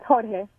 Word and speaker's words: Jorge: Is it George or Jorge? – It's Jorge. – Okay. – Oh, wow. I'm so sorry. I'm probably going to have a hard time pronouncing that. But how long have you two been together? – Well, it's whore, then hey.